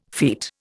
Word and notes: feat: Indian

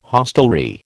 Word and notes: hostelry: American English

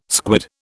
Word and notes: squid: British English

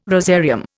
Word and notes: rosarium: Indian

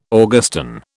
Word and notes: Augustan: British English

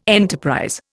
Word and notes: enterprise: Indian

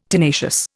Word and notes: tenacious: Indian

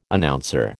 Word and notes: Announcer: American English

